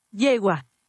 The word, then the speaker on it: yegua: Yegua.